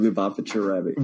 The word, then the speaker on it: Lubavitcher Rebbe: I'll never be able to say his name, Lubavitcher Rebbe. – Well, you got it. Well done.